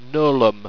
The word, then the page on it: gnolum: Owstwecian